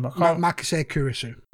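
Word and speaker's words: Makise Kurisu: Makase Kurisu.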